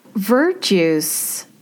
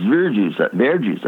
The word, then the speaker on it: verjuice: Verjuice, if I'm saying it correctly, is an ingredient I've never seen before. Describe that. – It's traditionally verjuice. I say it in French. I don't know how to say it. It's verjuice, I think. I think you said it right.